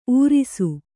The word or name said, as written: ಊರಾಸೆ